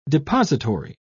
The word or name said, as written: depository